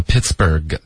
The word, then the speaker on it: Pittsburgh: OK，这个字呢，一般情况下，比如美国也有一个城市叫 Pittsburgh，它的结尾 burg 的这个发音跟它拼写的方式就是这样 burg。